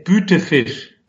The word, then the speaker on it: Bütefisch: Oye, Timo, eh, antes de, por favor, porque yo tengo familia política alemana, me gustaría que me dijeras eh, la pronunciación correcta de tu apellido. – Em, es Bütefisch.